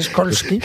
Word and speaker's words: Skolsky: No, señor, no es SH, es SKOLSKY.